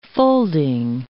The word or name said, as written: folding